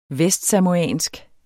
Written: vestsamoansk

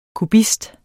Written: kubist

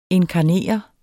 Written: inkarnere

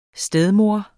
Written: stedmor

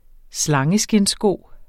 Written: slangeskindssko

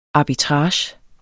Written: arbitrage

